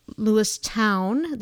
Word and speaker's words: Lewistown: Lewistown. There's a Lewistown in Montana. I like to call it Lewiston, but it's Lewistown. There's a Lewiston in Minnesota that's pronounced Lewiston.